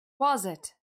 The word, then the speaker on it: Quasit: Quasit.